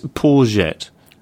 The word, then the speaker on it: Poyet: we should pronounce it, Gus Poyet. – Like courgette. – Like courgette, but with a P.